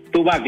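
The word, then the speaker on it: 2Back: Two Back, decimos. – Two Back. – Se escribe Dos Back, dos de acá.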